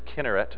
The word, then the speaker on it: Chinnereth: Or in your King James and in the English Bible, it's spelled C-H-I-N-N-E-R-E-T-H. But we would look at that and say Chinnereth, but probably better, closer to the Hebrew, is Kinneret.